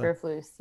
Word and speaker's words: Eberflus: Eric Bieniemy, Aaron Glenn, Arthur Smith, Matt Eberflus, and Eberflus, right? – Eberflus, yes.